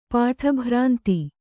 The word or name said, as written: ಪಾಠಭ್ರಾಂತಿ